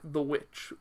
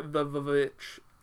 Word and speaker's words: The VVitch: And I also really like The Witch, or The Vvitch, however you would like to pronounce the title. It's supposed to be a W, but it definitely has two Vs in the title.